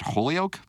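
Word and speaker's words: Holyoak: The dissenting commissioner is Melissa, Doug, do you pronounce it Holyoke? – Always have, yeah. – And Andrew Ferguson argued that the FTC was overstepping the boundaries of its power. Holyoke predicted the ban would be challenged in court and eventually struck down.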